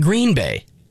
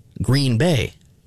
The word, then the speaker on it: Green Bay: Not Green Bay, Green Bay. It's the right way to say it.